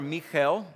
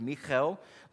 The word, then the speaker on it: Michal: Now Saul's daughter, Michal, that's how you pronounce it. I don't have enough water in my mouth to get the chel in there, but it's Michal, loved David, and she told Saul, and the thing pleased him.